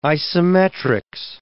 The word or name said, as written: isometrics